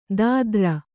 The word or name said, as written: ದಾದ್ರ